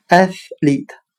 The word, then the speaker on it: athlete: 我们可以用谐音法来记忆这个单词 athlete，它的发音很像汉语的“ 爱使利他”，喜爱的爱，使用的使。